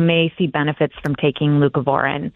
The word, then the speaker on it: Leucovorin: Leucovorin? – Yes.